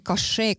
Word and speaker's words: Cachet: mis on kašee, kui ma nüüd õieti hääldasin seda? – ja väga hästi hääldatud.